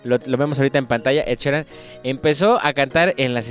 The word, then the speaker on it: Ed Sheeran: Ed Sheeran.